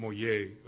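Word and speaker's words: Motyer: Would be pronounced Moyer, is what it would be pronounced. I think it's J. Moyer.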